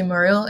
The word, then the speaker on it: Moreel: So Instagram, it's Ashley Muriel. If you guys do not know how to spell my last name, it's M-O-R-E-E-L, two E's.